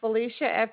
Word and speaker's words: Felicia: My name is Felicia, F-E-L-I-C-I-A.